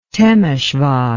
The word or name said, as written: Temesvar